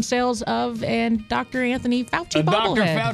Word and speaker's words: Fauci: Anthony Fauci.